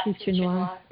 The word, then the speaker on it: future noir: I think in some, if you look it up on Wikipedia, they call it future noir or something, don't they? Is that how you pronounce it? – Yeah, future noir.